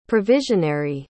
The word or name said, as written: provisionary